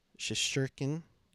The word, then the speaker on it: Shesterkin: Shesterkin, am I saying that right? – Yes.